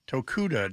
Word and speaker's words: Shigeo Tokuda: S-H-I-G-E-O, first name, last name Tokuda, Tango, Oscar, Kilo, Uniform, Delta, Alpha, Tokuda, just like it sounds.